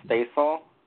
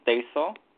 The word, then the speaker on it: Stacil: Hi, my name is Stacel. – I'm sorry, say that again. – My name is Stacel. – How do you spell that, please? – It's S-T-A-C, like cat, and I-L. – I'm sorry, C, did you say? – Yeah, S-T-A-C-I-L.